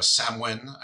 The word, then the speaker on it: Samhain: So we're going to take a little journey through hell in literature, because we figure hell is a very Halloween-y subject, or a Samhain, as I'm told it's properly pronounced in the Gaelic and Celtic.